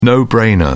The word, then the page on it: no-brainer: British English